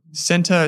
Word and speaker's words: Scentre: Center is spelled S-C-E-N-T-R-E. Group.